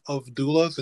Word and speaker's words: doula: So firstly, yes, you are pronouncing it right. It is doula.